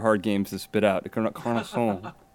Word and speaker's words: Carcassonne: Carcassonne, some of these other, German board games that have been reimported back here.